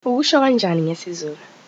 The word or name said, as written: Zulu